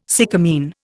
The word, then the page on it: sycamine: Indian